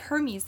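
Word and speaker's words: Hermès: A Birkin bag by Hermes. – Right. – It's spelled Hermes, but it's like Hermes, the god of magician.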